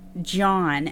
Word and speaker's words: Jean: Tamla's friends, John, and that's a female. It's spelt like Jean, but it's pronounced John.